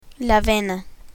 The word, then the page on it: vena: spanish